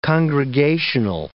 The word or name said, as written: congregational